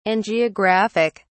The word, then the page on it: angiographic: British English